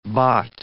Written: baht

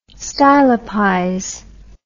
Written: stylopize

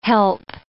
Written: help

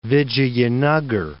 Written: Vijayanagar

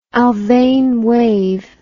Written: Alfven wave

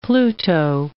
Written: Pluto

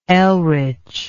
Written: elritch

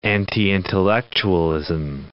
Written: anti-intellectualism

